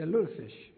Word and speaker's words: lutefisk: The lutefisk.